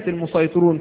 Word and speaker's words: المسيطرون: نقول لا، كلمة المسيطرون في الطور يستوي فيها النطق بالسين أو النطق بالصاد من طريق الشاطبية أو الطرق الأخرى،